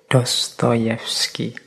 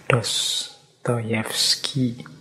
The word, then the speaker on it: Dostoyevsky: Jadi Dostoyevsky ini mengucapkannya harus agak pelan-pelan, nanti kalau kepleset ya. Fyodor Mikhailovich Dostoyevsky. Ini membacanya kurang lebih seperti itu. Kalau tulisannya kadang-kadang ada yang mengejanya Dostoevsky, tapi membacanya biasanya ya seperti tadi, Dostoevsky.